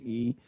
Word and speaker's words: Yi: The name of the book is The Million Mile Journey of Chiang Min Yi, and it's spelled Y-I, but it's pronounced E.